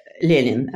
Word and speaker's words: Lenin: Lenin actually is how you say it.